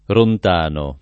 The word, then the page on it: Rontano: lingua italiana